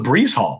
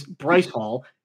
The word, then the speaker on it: Bryce Hall: Well, and there's also Breeze Hall, which makes it even more confusing. – All right, so for the phonetically challenged among us, it is Bryce Hall.